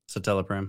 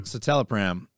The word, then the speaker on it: citalopram: Citalopram. – Citalopram, a selective serotonin reuptake inhibitor, or SSRI.